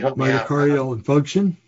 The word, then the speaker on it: myocardial infarction: Myocardial infarction? – That's it.